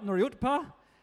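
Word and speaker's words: Nuriootpa: I always put the P and the T wrong here. Nuriyutpa. Yeah, is that right? I got it right. Nuriyutpa.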